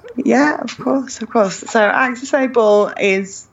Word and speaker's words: AccessAble: AccessAble, that's right.